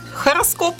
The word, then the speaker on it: хороскоп: Хороскоп. – Да, просто вместо «г» мы говорим «х» – хороскоп.